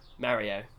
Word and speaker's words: Mario: How do you pronounce Mario? – Mario. Yeah, Mario. – There you go. Anyone that says it's Mario, literally just stop. – Mario. – Yeah. It's mostly Americans. It's pretty much all Americans.